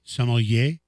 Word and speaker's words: sommelier: Sommelier.